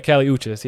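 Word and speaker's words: Kali Uchis: I don't want to butcher her name, but Callie Urchis. – That's Uchis, right? – Uchis, yeah, Callie Uchis, yeah.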